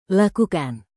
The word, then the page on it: lakukan: indonesian